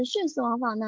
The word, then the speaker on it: 徇私枉法: 什么同音合并法呢？就问大家一个成语啊，是寻思王法呢，还是徇私枉法呢？在平时的时候，我们很容易读成什么呀？很容易读成第二声哈。那其实呢，它是第四声。